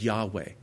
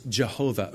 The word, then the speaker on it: Yahweh: And so down to this age, we're not 100% sure. Probably the most accurate is Yahweh. The most popular is Jehovah or Yehovah.